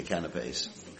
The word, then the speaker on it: canapé: Yeah, canapés. Canapé is actually the French pronunciation.